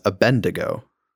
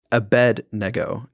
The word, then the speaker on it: Abednego: I've always said that name as Abednego. Like Abende, but Abednego is how you say it.